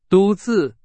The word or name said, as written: 独自